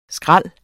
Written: skræl

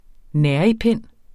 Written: nærigpind

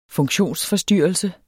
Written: funktionsforstyrrelse

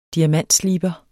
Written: diamantsliber

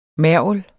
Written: mergel

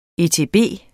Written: etb

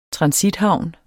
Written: transithavn

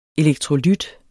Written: elektrolyt

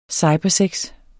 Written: cybersex

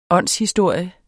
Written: åndshistorie

